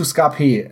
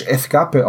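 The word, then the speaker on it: Ska-P: Also, um zurückzukommen zu Scapé, ähm, das ist die deutsche Aussprache. Das ist eine Ska-Band aus ähm, Spanien und ich habe mir sagen lassen, dass das ein Wortspiel ist zwischen der ähm, Musikrichtung Ska und diesem P, dass das auf ähm, Spanisch Escape ausgesprochen wird und halt Flucht bedeutet.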